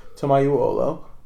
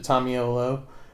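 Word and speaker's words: Tomaiuolo: The correct, like, pronunciation phonetically is Tomayuolo, which is a little bit difficult. My dad just says it Tomayuolo. I say it Tomayulo.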